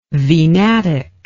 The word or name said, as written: venatic